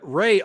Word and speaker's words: Rey: He's a Mariner here, but Ray, R-E-Y is how you spell it. The Reds did have a player who spelled his name R-E-Y, right?